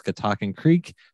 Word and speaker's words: Catoctin Creek: And folks, Catoctin Creek, that's how you say it.